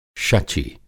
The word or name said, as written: शचि